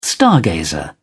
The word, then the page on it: stargazer: British English